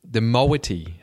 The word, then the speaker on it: moiety: Tell us a little bit about kinships, because I was fascinated with this breakdown on the website, and especially the moiety.